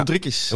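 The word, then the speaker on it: Uldriķis: Uldrix. Ja. Uldrikis. Ja. Ik wou het bijna ja, in... Fries uitspreken.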